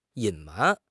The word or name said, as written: 隐瞒